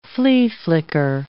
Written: flea-flicker

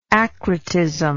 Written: acrotic

